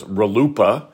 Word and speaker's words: RLUIPA: This is universally pronounced as RLUIPA. It sounds like a joke, but RLUIPA, and the court held that RLUIPA protects Mr. Ramirez's claim in this case.